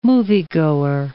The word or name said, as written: moviegoer